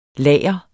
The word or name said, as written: lager